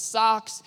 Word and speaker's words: sarx: the Greek word for flesh is sarx. It, it rhymes with sucks, therefore your flesh sucks.